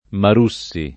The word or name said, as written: Marussi